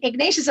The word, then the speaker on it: Ignatius: So Ignatius of Loyola is one of those great movie stories, I think.